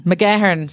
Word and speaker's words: McGahern: McGahern. – Sorry. New York accent. Listen, I apologize for it. You'll hear it more as we go on in the podcast. So the John McGahern Award for Literature, and that recognized previous and current works.